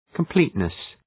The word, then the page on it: completeness: kəm’pli:tnıs